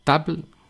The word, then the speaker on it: table: No hacer table ni table, sino algo intermedio. Table. Table. Es decir, que se note que haya dos sílabas, pero la segunda sílaba termine en una especie de E.